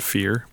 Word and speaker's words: Phere: I'm going to start a sound thing that's called Fear, but it's spelled P-H-E-R-E. – Okay. It's basically like sphere, but without the S.